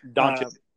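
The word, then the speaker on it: Dončić: Doncic.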